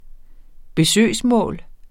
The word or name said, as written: besøgsmål